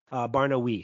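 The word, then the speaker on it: Barnaoui: So it's the French pronunciation of "we." Barnoui.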